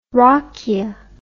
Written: rakija

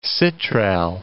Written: citral

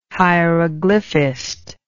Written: hieroglyphist

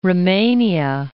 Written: Rumania

